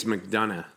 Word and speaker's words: McDonagh: I think it's McDonagh.